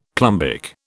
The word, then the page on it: plumbic: British English